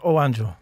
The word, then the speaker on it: O Anjo: Yes, that's right. O Anjo. That's the right pronunciation.